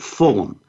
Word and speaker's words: Fulghum: And this is by Robert Fulham, F-U-L-G-H-U-M. It's pronounced Fulham.